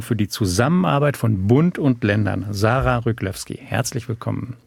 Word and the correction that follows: Ryglewski: Riglewski spricht sich das aus.